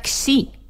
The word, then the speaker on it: Xi: Y la siguiente es una que se pronuncia Xi. – ¿Qué podía ser Xi Jinping? – Que era como, que podía sonar como Xi y que podía sonar como Xi Jinping.